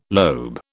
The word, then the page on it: lobe: British English